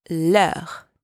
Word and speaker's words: leurs: But if the noun is plural, you use leurs with a silent S at the end.